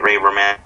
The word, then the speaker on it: Romano: Also, Ray Romano, that's how you say his name, right? Ray Romano. – Romano, yeah.